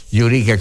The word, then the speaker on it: Eureka: Eureka, come gli americani pronunciano la parola greca Eureka.